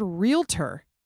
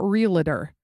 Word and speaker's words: realtor: Also the word realtor, two syllables, not realtor.